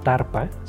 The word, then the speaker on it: Tharpa: También la tradición tiene en Spotify la editorial de la tradición que se llama Tarpa, se escribe T-H-A-R-P-A, Tarpa España.